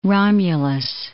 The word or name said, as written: Romulus